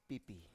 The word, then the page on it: pipi: Biak